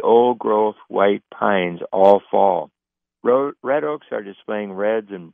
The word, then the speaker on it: redd: He photographed a pair of brown trout in the process of depositing eggs and milk in their red. It's spelled R-E-D-D.